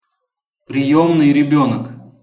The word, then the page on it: priemnyj rebenok: Russian